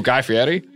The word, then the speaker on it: Guy Fieri: Ooh, Guy Fieri? – Wow, you pronounced it so perfectly.